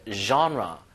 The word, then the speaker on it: genre: 이게 이제 그 영어 원의 단어가 아니어가지고 장르란 발음이 이제 genre. genre. music genre.